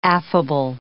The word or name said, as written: affable